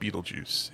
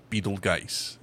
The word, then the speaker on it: Betelgeuse: I am so disappointed in Jesse in this one, because I literally told him the pronunciation is not Beetlejuice. It's Betelgeuse. It's not Beetle. It's Betelgeuse. It's Betelgeuse.